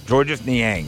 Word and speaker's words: Georges Niang: Let me make sure I get his name right. Georges Niang? – Yeah, Georges Niang.